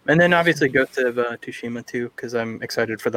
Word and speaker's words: Tsushima: I just like to say Tsushima. I don't think you pronounce the T. – Tsushima. – It's silent. It's a silent T. Tsushima.